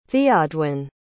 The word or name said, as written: theodwin